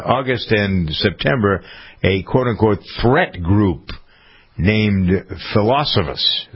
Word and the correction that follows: Phosphorus: Is that how you pronounce that, you think? Phosphorus, not Philosophus. Sound like Mr. Snopinopagus.